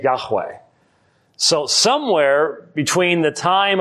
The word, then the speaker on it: Yahweh: Yahweh has two syllables.